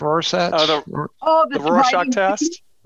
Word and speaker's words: Rorschach: How do you say it? the Rorschach? – Rorschach test? – Rorschach test, yeah.